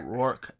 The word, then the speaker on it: Rourke: Rourke. – Mr. Rourke. Yeah, you said it right. How you said it is how you say it.